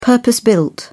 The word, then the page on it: purpose built: British English